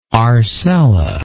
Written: arcella

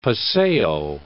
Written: paseo

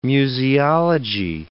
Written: museology

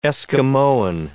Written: Eskimoan

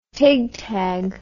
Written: tigtag

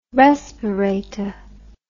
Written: respirate

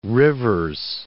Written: Rivers

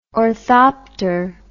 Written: orthopter

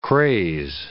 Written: craze

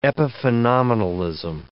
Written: epiphenomenalism

